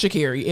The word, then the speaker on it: Sha'Carri: Again, we say all the time, if you can say Corsesi and all these other Vankoswaski, you can pronounce Sha'Carri.